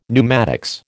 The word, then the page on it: pneumatics: American English